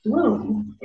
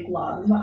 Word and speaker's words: Blum: And in Germany or all of Europe, my husband's, our last name is pronounced Blum. In the US, they say Blum.